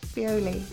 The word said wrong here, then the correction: Beoley: No, it's not Bioli, it's pronounced Bealey.